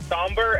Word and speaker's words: sombr: Somber, S-O-M-B-R. – Oh, yeah, Somber.